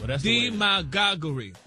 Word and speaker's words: demagoguery: Demagoguery. – That's the way the dictionary broke it down. – The pronunciation, like the emphasis, is on the D.